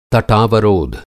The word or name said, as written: तटावरोध